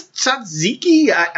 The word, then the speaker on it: tzatziki: What is this tzatziki? – You said it right.